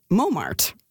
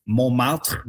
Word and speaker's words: Montmartre: the English-speaking locals there say it Montmartre.